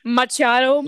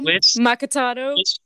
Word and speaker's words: macchiato: This is macchiato all over again. – Machado. Macchiato.